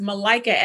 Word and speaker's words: Malika: I say it's Malika, as in I like a Malika.